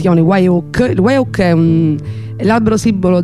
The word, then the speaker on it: Wye Oak: Loro prima si chiamano Monarch e hanno realizzato 3 album in tutto, l'ultimo appunto col nuovo nome, che è Wyoak, si scrive W-Y-E-O-A-K con la K finale.